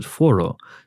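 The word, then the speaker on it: Fårö: The gentleman who advised me a couple of weeks ago that Alexander Skarsgård is actually pronounced Skorsgård, and then you hear the island, Faro, that's how you pronounce it if you say it, if you read it as it's written, F-A-R-O. I would pronounce that Faro or Pharaoh, but you hear it in the movie, and they actually pronounce it Foro. So... That's cool. because of the Swedish pronunciation and whatnot.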